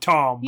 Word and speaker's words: Thom: All right, well, elsewhere, but at the same time, Matt and Rand are traveling with their new friend, Thom, who I think is actually just called Tom, but yeah. I'm going to call him Thom because it's spelled T-H-O-M.